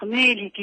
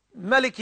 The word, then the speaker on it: مالك: بدي أسأل إيه مالك يوم الدين في سورة الفاتحة، هل تنطق مالك يوم الدين أو مالك يوم الدين؟ – الحمد لله والصلاة والسلام على رسول الله وبعد، فالقراءتان صحيحتان. ملك يوم الدين بلا ألف، ومالك يوم الدين بإثباتها القراءتان صحيحتان، بارك الله فيك.